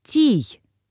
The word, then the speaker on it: Тий: «Тий» произносится как «тий», ударение на первый слог.